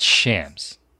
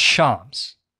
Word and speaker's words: Shams: It's not Shams. It's Shams. If you're covering sports, you should probably know how to say his name correctly.